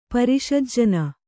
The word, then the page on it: ಪರಿಷಜ್ಜನ: Kannada